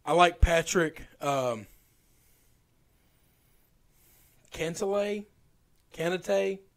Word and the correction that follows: Cantlay: Cantlay? – Is that it?